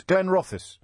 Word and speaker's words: Glenrothes: Rena is in, I never know how to pronounce this, Glenrothes. – That's correct.